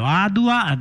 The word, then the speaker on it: Adwa: però Adua è scritto A-D-W-A, perché Adua. La U è la W, si può trovare, del 1999 questo video, Adua di Gerima Haile,